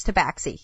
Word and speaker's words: Tabaxi: There's also Firbolg, as well as Lizardfolk, and I always hate the pronunciation of this. Tabaxi? – I think it's Tabaxi, yeah.